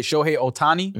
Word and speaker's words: Shohei Ohtani: Shohei Ohtani? – Yeah.